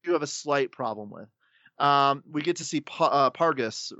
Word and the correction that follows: Pagos: Is that how you pronounce it? Is it just Pargus or Pargius? Pagos. Pagos. – Yeah. – Sorry, there's no R.